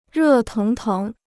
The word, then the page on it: 热腾腾: rè téng téng